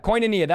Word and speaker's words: koinonia: Koinonia. That's how you say it. Okay. Koinonia. Thank you.